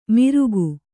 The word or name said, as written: ಮಿರುಗು